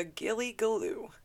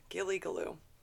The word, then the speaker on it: Gillygaloo: This one is called the Gilligaloo. That's spelled G-I-L-L-Y-G-A-L-O-O. Gilligaloo.